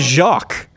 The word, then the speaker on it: Jacques: It's Jacques.